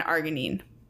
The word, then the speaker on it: arginine: um, and your body can produce it from amino acids, blah, blah, blah, amino acids, glycine, and arginine. Is it arginine? – That's how I've heard it pronounced.